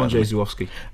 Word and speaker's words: Andrzej Żuławski: Andrzej Żuławski.